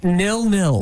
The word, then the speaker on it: nil-nil: Or apparently it's, how do you say? – Nil-nil. – Nil-nil. – Yeah, not Neil. We're not calling a guy named Neil.